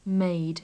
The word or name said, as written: made